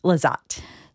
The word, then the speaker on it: Lezotte: Lezotte.